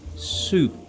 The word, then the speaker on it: soup: No, Anna, not soap. It's pronounced soup. You wash with soap, but you eat soup.